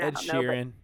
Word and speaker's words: Ed Sheeran: Is it Ed Sheeran or Ed Sheeran? – Ed Sheeran, yeah.